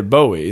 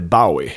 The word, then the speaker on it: Bowie: Men vi är överens i alla fall att man säger Bowie, inte Bowie.